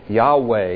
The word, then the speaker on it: Yahweh: It is the word Yahweh is usually how it's pronounced in English, or that's how we think it was pronounced. It is Yahweh.